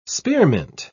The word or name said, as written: spearmint